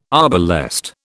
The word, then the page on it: arbalest: British English